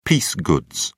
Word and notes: piece goods: British English

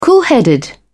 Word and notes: coolheaded: British English

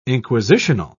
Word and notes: inquisitional: British English

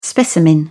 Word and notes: specimen: British English

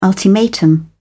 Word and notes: ultimatum: British English

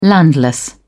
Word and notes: landless: British English

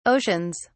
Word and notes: oceans: British English

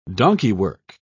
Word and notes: donkeywork: British English

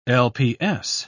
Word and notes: lps: British English

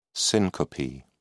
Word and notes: syncope: British English

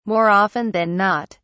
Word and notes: more often than not: British English

